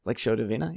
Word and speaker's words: Lectio Divina: Meditating on passages like in, oh, I always butcher the translation, Lectio Divina, oh, yeah, that's it, right? I think I did okay there, yeah, Lectio Divina, yeah.